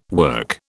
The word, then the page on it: work: British English